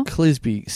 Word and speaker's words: Clisby: Sarah, I'm not mispronouncing that, hopefully, because it's spelled S-E-R-R-A. Sarah. – Sarah. – Sarah. Sure. Clisby. C-L-I-S-B-Y. – Clisby. Yeah.